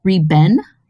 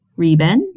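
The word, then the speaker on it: Riben (日本): In modern Chinese, these two characters is pronounced, it looks like, Riben. Riben, Mandarin Chinese pronunciation. However, and very interestingly, in the days of the explorer Marco Polo, and even nowadays, in the southern part of China, these characters were pronounced as Jipeng or Zupeng. And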